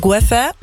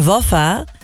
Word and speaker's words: Weffe: Ik heet natuurlijk Weffe. Maar niemand kon dat vroeger uitspreken. Iedereen zei altijd toen destijds op school. Ik was natuurlijk een van de weinige buitenlanders. Maar één andere buitenlander bij mij op school. Mm-hmm. En ze konden mijn naam niet uitspreken. Dus ze Waffa, Waffa. Dus op een gegeven moment zei iedereen Waffa.